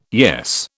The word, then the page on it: yes: British English